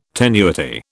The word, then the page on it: tenuity: British English